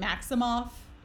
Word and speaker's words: Maximoff: Was it Maximoff? Is that how you pronounce her last name? – Mm-hmm. Yep. Yeah.